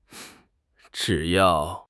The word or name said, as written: zhi3 yao4